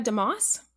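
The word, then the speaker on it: DeMoors: So my name is Dia Demores, D-I-A, D-E space capital M-O-O-R-S.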